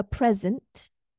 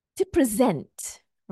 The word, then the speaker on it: present: So for nouns, the stress falls on the first syllable, okay? For nouns. So here is our noun, a present. Present. I have a present for you. It's an item. It's a thing. That's a noun. Okay. I have a present for you. But if it's a verb, it falls on the second syllable.